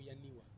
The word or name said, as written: aniwa